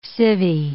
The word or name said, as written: civie